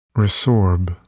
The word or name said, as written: resorb